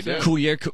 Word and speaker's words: Coulier: in Fairview Heights, Mr. Dave Coulier is in town all weekend long. Did I pronounce that right? – Coulier? Yeah. – I think so. – Yeah, Coulier. It's Dave Coulier.